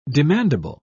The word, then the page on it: demandable: British English